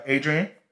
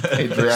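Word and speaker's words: Adrián: So my keeper is actually Adrian, to pronounce it with the Spanish accent. – Adrian.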